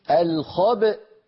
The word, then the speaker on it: الخبأ: لأن في أصل الكلمة همزة متحركة في الوصل، لما وقفنا عليها سكنت الهمزة فلا بد من نطقها، فنقول الخبأ